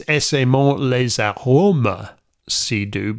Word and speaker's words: Essaimons les arômes si doux: And when you sing it, it's Essaimons les arômes si doux, because a one-syllable word can become two syllables when sung in French, which you don't really do in English.